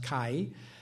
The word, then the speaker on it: Kai: You'll notice his name is Kai, and this is the K sound. This is it's an aleph, which is in the back of the throat, going ah, and then like our Y, so it's pronounced Kai.